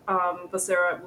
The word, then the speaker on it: Becerra: And then we have, I'm going to butcher this name, Javier Becerra, is it? – That's Becerra. – Right.